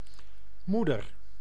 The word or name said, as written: mère